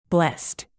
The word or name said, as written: blessed